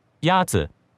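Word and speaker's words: Yatsu (鸭子): Yatsu is pronounced with a first tone. In Mandarin, there are four tones, which are essential to distinguish the meaning of words. The first tone is a high-level tone.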